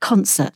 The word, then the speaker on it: Consett: I remember you just talking about it before, called Concert. Is that the right pronunciation? – Yes, yeah.